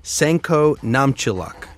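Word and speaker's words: Sainkho Namtchylak: Senko Namchiluk.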